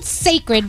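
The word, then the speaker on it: Cécred: It's called Sacred, but it's spelled C-E-C-R-E-D.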